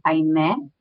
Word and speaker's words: Aimee: My name is Aimee Nunez, or as my mom would say it right. It's Aimee. That's my name. I'm Aimee Nunez.